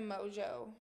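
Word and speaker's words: Mongeau: Tana Mojo. – I think it's Mongo or something. – No, it's Mojo. – But there's an M. What Mojo. Is it? It's Mojo? – Yes, the N is silent.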